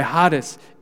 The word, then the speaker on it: charis: The Greek word for grace here that's used is charis.